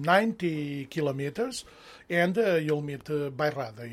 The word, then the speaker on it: Bairrada: who is a Portuguese wine producer from Beirada, right? Did I say that correctly? – Beirada. How do you spell it? B-A-I-R-R-A-D-A. Beirada. – All right. Now, Beirada. – That's it.